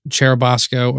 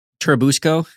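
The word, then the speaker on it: Churubusco: And it was our first hate emails we ever got because I mispronounced Cherubasco or Cherubusca. It's like caboose. – Cherubusco? – Yeah. Okay. And I always say it Cherubasco like Bosco sticks.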